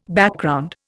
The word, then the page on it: Background: Indian